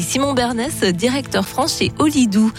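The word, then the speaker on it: Holidu: J'ai bien prononcé ? – Olidou, on dit.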